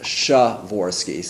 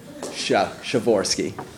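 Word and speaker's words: Przeworski: His name is pronounced Szaworski, so P-R-Z-E-W is just Sha. Szaworski. There's a kind of silent P that you sort of imagine you're saying, Szaworski. So I think there's a kind of whisper of that P there somewhere, but it's basically Szaworski. Dimitro can probably say it with more. – My said it right, K-H-U, like Kutki, but it's red Kutki.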